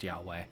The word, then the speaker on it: Yahweh: So it's actually the contraction pronounced Yahweh, but it's I am that I am.